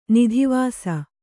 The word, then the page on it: ನದಿವಾಸಿ: nadi vāsi